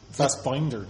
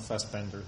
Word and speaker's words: Fassbinder: Fassbinder, not Fassbender. – Yeah. It's pronounced Fassbinder. – Yeah, but that... Yeah, the one that's spelled was I, not E.